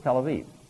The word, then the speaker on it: Tel Aviv: Spelled with B's instead of V's, but it's Tel Aviv.